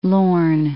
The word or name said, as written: Lorn, Firth of